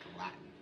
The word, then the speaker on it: Gallatin: The Gallatin Range, I believe, is how you pronounce it. – Gallatin.